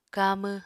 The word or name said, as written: kamw